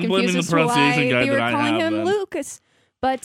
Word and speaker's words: Lukes: Has it been pronounced Lucas? – This whole time. – Why does this say Luke's then? – Because that's how his last name is spelled. – I bring it up because it says it's spelled Luke's. Pronunciation guide says Luke's.